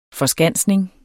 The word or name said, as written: forskansning